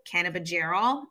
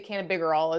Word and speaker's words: cannabigerol: So I know some of the major minor cannabinoids are CBG, which is cannabigerol. Am I saying that right? – Yep. Okay. I've heard it say cannabigerol as well.